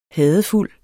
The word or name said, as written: HADEFULDE